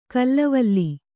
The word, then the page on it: ಕಲ್ಲವಲ್ಲಿ: kallavalli